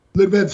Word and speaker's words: Lviv: How do you pronounce that? – Lviv sounds good.